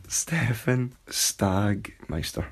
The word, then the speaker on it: Sagmeister: Stefan Sagmeister.